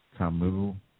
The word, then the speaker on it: Kamau: It's W. Kamu Bell. And Kamu is spelled K-A-M-A-U.